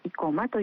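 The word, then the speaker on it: 往馬: はい、今朝は毎年 10 月スポーツの日の前日に開催される地元生駒市にある生駒大社の火祭りについてお話しします。 – はい、生駒大社の生駒は生きるに駒と書く生駒市の生駒ではなく、往復の王に馬と書いて生駒と読みます。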